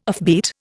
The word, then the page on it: offbeat: Indian